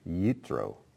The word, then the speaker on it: Yitro: So I'm sticking with the Hebrew pronunciation, which is Yitro.